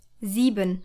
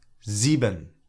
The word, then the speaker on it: Sieben: The S in Sie sounds like the English letter Z, like in zoo. To take another example, the number 7 is Sieben. – Sieben.